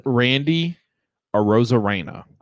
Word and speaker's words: Arozarena: Randy Arozarena.